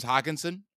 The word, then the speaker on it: Hockenson: And as I mentioned, you know, I'm gonna try and say this man's name as many different ways as possible, but I'll start with the correct way to say it. Thomas James Hockenson is in a revenge game, and people are gonna be looking to naturally run back this game with the Minnesota side.